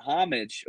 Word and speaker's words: homage: Romy says it's pronounced homage, not homage.